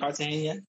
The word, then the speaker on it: Cartagena: We were going to Cartagena. Is that how you say it? – Cartagena.